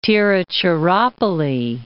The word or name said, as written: Tiruchchirappalli